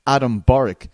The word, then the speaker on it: Adam Borics: It's undefeated prospect and Bellator featherweight, Adam Borics.